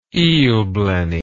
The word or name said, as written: eelblenny